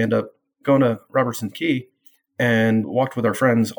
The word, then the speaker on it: Quay: We went to visit friends that lived in Robertson Quay or Quay, depending upon how you like to say it. I've heard it on tour buses. I've heard Clark Quay, and it's spelled Q-U-A-Y, and they call it Clark Quay.